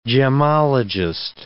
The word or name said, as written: gemmologist